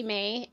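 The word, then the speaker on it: Mae: On the internet, I go by Haley May, and May is spelled with an E at the end versus a Y.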